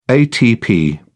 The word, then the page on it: atp: British English